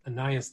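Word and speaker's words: Anaïs: And the other is a diarist by the name of Anais Nin. It's hard to pronounce, A-N-A-I-S.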